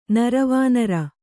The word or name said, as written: ನರವಾನರ